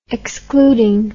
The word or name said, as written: excluding